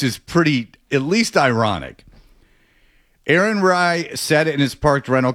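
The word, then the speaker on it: Aaron Rai: Aaron Rye.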